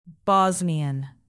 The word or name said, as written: bosnian